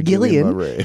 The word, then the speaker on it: Guillain-Barré: Guillain-Barré, yeah. – I can say it. Guillain. – Yeah, Guillain-Barré.